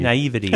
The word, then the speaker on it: naivety: Naivety, I think, is exactly how you say it.